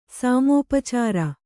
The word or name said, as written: ಸಾಮೋಪಚಾರ